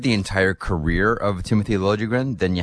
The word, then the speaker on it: Liljegren: So the Leaf pick, Timothy Liljegren, or Liljegren, I guess, is the correct pronunciation.